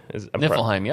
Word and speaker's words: Niflheim: Niflheim? – Niflheim, yep.